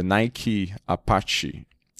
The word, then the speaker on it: Nike Apache: Now, the Nike Apache, am I pronouncing that correctly? – Mm-hmm.